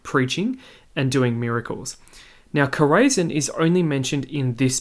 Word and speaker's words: Chorazin: So these are two towns, Chorazin, or sometimes pronounced Chorazin, and Bethsaida.